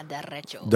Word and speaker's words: derecho: It, it was a, a, correct me if I pronounce this wrong, a derecho. Derecho. Yeah. Derecho. – A derecho event, yes. Der, derecho. derecho. I gotta, do I roll the R on that one? – Yeah, derecho.